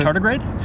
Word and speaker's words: tardigrade: The tardigrade? – Thank you.